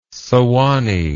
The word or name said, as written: Suwannee